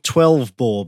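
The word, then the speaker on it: XII Boar: and one called 12 Boar, but it's spelt XII and then boar as in a wild boar.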